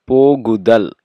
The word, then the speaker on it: pogudal: How do you say the going? Like the going is good. Or the going. Pogudal. – Pogudal. – Pogudal. You have to have the tongue between the teeth. Pogudal.